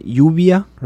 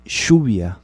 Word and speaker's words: lluvia: So uh, lluvia, right? – Okay. – Lluvia. In Uruguay, we would say lluvia.